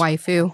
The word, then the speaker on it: waifu: Am I saying that right? Waifu? Waifu? Waffles? – Waifu.